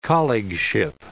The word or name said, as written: colleagueship